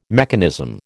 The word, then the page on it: mechanism: American English